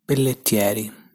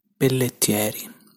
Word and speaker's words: Pelletieri: So this is how in standard Italian accent you're supposed to pronounce that surname. Pelletieri, Pelletieri. This is how I would pronounce it with my local accent. Pelletieri, Pelletieri. And this is my fake, God forgive me, Sicilian accent. Pelletieri, Pelletieri. As you can hear, they're not that different.